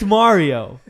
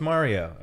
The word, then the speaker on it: Mario: You pronounce the A differently. – That's not wrong. My name. Yeah, it's Mario. We all know it's Mario, okay?